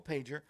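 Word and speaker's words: Pajer: Her last name is spelled P-A-G-J-E-R, P-A-J-E-R, Nicole Pager.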